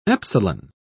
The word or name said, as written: epsilon